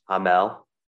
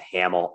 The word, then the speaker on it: Hamel: It's Hamel. – Yep. – I'm going to Americanize that to Hamel, I'm certain, before we're done today.